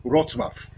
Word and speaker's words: Wrocław: Die Stadt, ehemals Stadt Breslau, heißt Wrocław.